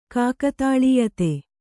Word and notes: ಕಾಕತಾಳೀಯತೆ: Kannada

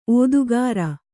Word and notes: ಒತ್ತಕ್ಕರ: ottakkara

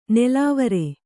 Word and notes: ನೆಲವರ: Kannada

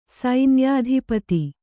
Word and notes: ಸೈನ್ಯಾಧಿಪತಿ: kannada

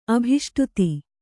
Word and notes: ಅಭೀಷ್ಟಿತ: abhīṣṭita

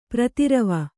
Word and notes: ಪ್ರತಿರವ: Kannada

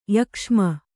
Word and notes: ಯಕ್ಷ್ಮ: yakṣma

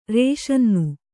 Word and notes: ರಸಾನ್ನ: rasānna; kannada

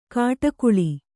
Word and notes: ಕಾಟಕುಳಿ: kāṭakuḷi

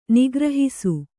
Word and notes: ನಿಗ್ರಹಿಸು: nigrahisu

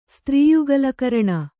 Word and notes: ಸ್ತ್ರೀಯುಗಲಕರಣ: kannada

